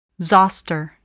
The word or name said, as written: zoster